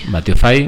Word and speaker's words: Watiofy: Batiofy. – Bien, lo he dicho bien. Batio de Batio, W-A-T-I-O sí. y F-Y, ¿no?